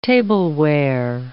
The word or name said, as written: tableware